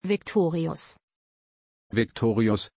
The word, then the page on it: viktorius: German